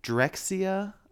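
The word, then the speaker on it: Drexciya: Called Drexia. I believe is what it's pronounced. It's D-R-E-X. – I know them. – C-I-Y-A.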